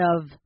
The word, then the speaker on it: of: 如果你想要发音的话，这边你要念成 one of the oldest。好，one of，所以这边是 v 的音。